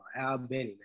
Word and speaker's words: Albany: But yeah, we supposed to say Albany. Yeah, I'm saying it wrong. Albany, man.